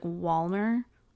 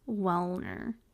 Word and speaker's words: Wellner: Wellner. Wellner. I feel like sometimes it rolls off the tongue as like Walner. Wellner. I have to really be doing my radio voice to pronounce it correctly.